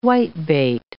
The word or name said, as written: whitebait